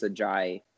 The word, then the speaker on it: Ajayi: And then 82 minutes in, how do you say this? Ajayi? Scores for West Brom. – Anything like the former Miami Dolphins running back, it's Ajayi. – Yeah, that's right.